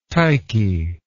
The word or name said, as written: Tyche